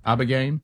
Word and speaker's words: Ibogaine: Is it Ibogaine? Am I saying that right? – Ibogaine. – Ibogaine.